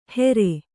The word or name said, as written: ಹೆರೆ